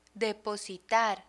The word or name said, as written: depositar